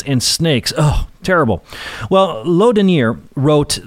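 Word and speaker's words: Laudonnière: Well, 2 years later, a man named Laudonniere, and I'll spell that, L-A-U-D-O-N-N-I-E-R-E, Laudonniere, built a fort on the St. John's River in Florida.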